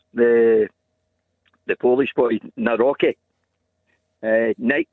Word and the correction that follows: Nawrocki: Yeah, I think the Polish pronunciation is Nawrocki from what I've seen, but certainly I'm sure there'll be plenty of ways it's pronounced over here.